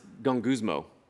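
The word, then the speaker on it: gonguzmo (γογγυσμός): the Greek word that Paul uses for grumbling is pronounced gonguzmo.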